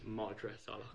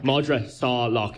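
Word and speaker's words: Madra Salach: Madras Salak. – If you think of saw and a lock.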